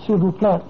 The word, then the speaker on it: s'il vous plaît: Or s'il vous plaît. You don't pronounce the S in... – Exactly, s'il vous plaît. You never say s'il vous plaît.